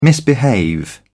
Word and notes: misbehave: British English

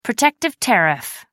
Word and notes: protective tariff: American English

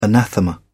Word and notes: anathema: British English

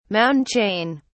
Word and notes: mountain chain: British English

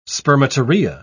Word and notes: spermatorrhoea: British English

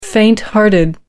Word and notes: fainthearted: American English

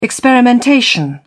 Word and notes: experimentation: British English

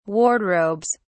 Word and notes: wardrobes: British English

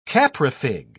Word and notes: caprifig: British English

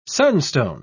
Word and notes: sunstone: British English

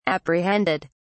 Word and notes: apprehended: British English

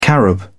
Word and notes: carob: British English

